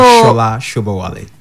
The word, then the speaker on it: Sola Sobowale: So Shola Shobowale. – Is Shola? This actually how you spell it? Yeah, actually, some people spell it like that, Sola, right? But I think it is Shola Shobowale.